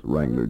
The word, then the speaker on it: Wrangler: Westinghouse portable TVs, Toastmaster hair dryers, Schaefer pen and pencil sets, entry blank at any store that sells Wrangler jeans. And ask about the free golden key ring inscribed with Wrangler's unforgettable message, remember, the W is silent.